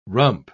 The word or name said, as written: rump